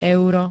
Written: euro

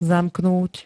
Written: zamknúť

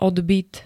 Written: odbyt

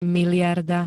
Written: mld

